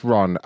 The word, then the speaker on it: Ron: Yes, it's Ron. R-O-N.